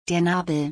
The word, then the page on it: der Nabel: German